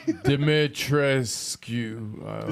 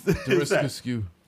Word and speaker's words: Dimitrescu: Dimitrescu. – It's not skew, but yes. – Dimitrescu.